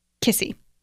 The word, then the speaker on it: Kesse: And Kissy. – Kissy. – Which is spelled K-E-S-S-E, which does not make a lot of sense.